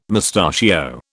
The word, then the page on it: mustachio: British English